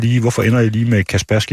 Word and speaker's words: Kaspersky: Jeg skal lige, når jeg har fat i dig, hvordan udtaler man det her? Kaspersky eller Kaspersky? – Det er svært for rigtig mange, men den rigtige måde at sige det på, det er Kaspersky Labs.